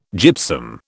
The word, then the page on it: gypsum: British English